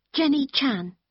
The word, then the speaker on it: Jenny Chan: Jenny Chan. – Can you spell that, please? – Yes. J-E-N-N-Y-C-H-A-N.